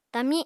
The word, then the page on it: tami: apatani